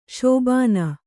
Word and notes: ಶೋಭನ: śobhana

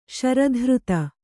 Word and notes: ಶರಧೃತ: śara dhřta

